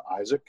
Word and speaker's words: Isaac: Um, it's uh, Isaac I S A A C uh,